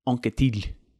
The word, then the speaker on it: Anquetil: Anquetil, to say it with the correct pronunciation, although my daughter who speaks French will probably not like that pronunciation.